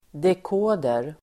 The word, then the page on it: dekoder: dek'å:der